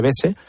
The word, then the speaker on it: Beuze: La doctrina, la sentencia del caso Beuze, se escribe Beuze, se dice Bese, ¿sí?